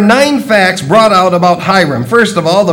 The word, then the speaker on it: Hiram: Verse 13, now King Solomon sent and brought Hiram, and that's how you pronounce it, Hiram from Tyre.